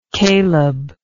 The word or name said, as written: Caleb